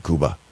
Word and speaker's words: Koba: It's spelled K-O-B-A. They're pronouncing Cuba.